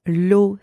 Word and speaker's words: eau: The second word, l'eau, spelled E-A-U, means water. And the letters E-A-U together are also pronounced O. And finally, O, spelled H-A-U-T, means high or tall.